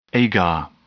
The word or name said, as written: agar